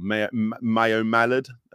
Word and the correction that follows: Maui Mallard: game as well. – Maui Mallard, yeah.